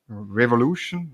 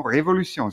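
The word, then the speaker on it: Révolution: Und sie ist am äh, Gurtenfestival, das letztes Wochenende war, äh, losgetreten worden vom Kollektiv Café Revolution Revolution, no, Revolution. Sie haben das EG auf dem E, also muss es französisch ausgesprochen werden.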